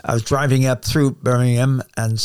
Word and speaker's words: Birmingham: You Americans say Birmingham for the same spelling. In England, we call it Birmingham, and that's where he had gone to live the last couple of years, near his daughter and son-in-law.